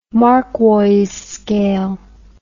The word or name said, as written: marquois scale